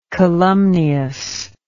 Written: calumniously

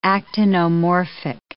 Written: actinomorphic

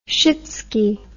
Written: shitsky

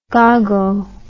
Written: gago

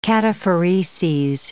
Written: cataphoreses

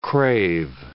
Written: crave

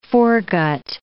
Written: foregut

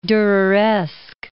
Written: Dureresque

